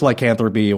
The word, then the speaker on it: lycanthropy: Lycanthropy. Is I'm saying that right? – Yeah, that's the whole person believing they can turn into a wolf or stories about them, but it's lycanthropy, or if you're the person, you're a lycanthrope.